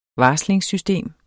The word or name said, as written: varslingssystem